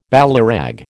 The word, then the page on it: ballyrag: American English